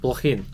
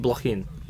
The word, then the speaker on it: Blokhin: Blokhin, okay. Yeah. So Yuriy Blokhin.